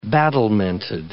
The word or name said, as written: battlemented